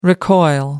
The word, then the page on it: recoil: American English